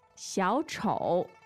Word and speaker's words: 小丑: 小丑. – The tones are? – Two third tones. – Mm. Third and third.